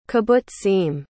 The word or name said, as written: kibbutzim